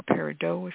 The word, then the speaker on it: peridot: But peridot or peridot, if you want to pronounce it the French way, is good, and which is a green olivine.